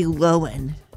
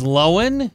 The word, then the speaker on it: Lohan: This is Lindsay Lohan. – Lohan? – Not Lohan. – Her name is Loewen.